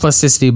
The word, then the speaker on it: Plasticity: It's pronounced Plasticity, but it's a pun on Plastic City because it's about it's a puzzle platformer where you're exploring a sort of looks like post-apocalyptic world.